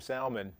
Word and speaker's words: salmon: Why are you saying salmon? – Because that's how you pronounce it here. – Oh, where I come from, we say it differently. – I know. – There's an L in it.